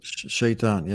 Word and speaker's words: Shaitan: Shaitan, yes.